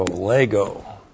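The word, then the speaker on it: lego (λέγω): L-E-G, long O, lego.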